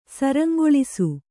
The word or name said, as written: ಸರಂಗೊಳಿಸು